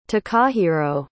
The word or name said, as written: takahiro